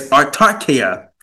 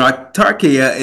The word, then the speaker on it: autarkeia: And the Greek word for contentment, which is used within this verse, is artarkeia. Okay, I'm gonna say that again. It's autarkia, and autarkia is spelled A U T A R K E I A.